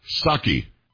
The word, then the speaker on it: Psaki: Psaki. – Psaki? – Psaki. It's not Psaki. It was just Psaki. You didn't pronounce the P.